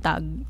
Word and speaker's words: Tag: Kasi yung pagtatandaan natin sa German kasi, yung pronunciation nila mostly ng G kapag dulo, parang K. So, tag. Hindi siya tag. Hindi siya babasay na tag. Tag.